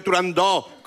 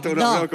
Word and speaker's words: Turandot: ¿Ves? Yo también lo digo mal. Si es que son muchos años diciéndolo mal. En el mundo exótico en el que vive Turandot, con O abierta. – Turandot, con O abierta.